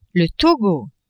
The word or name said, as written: Togo